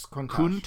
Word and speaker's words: Countach: Yes, Countach.